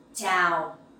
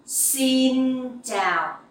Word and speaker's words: Xin chào: The X sound you will pronounce like s in English. Like the S sound in English. Okay, it's like in speak. – So, xin. – And the sound chờ in chào. You can pronounce like in champion.